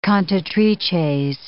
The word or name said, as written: cantatrices